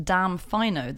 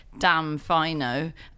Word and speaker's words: Damfino: I do think that it's probably pronounced Damfino, the boat. Although, obviously, you don't hear them say it, but there is. – Do they rhyme it with rhino halfway through the film in a note?